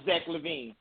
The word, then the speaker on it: Zach LaVine: trade Oubre for Zach LaVine and you make a run. My opinion. – That's good. That's a good pronunciation. – Zach LaVine, we'll bring this caller on right now, guys.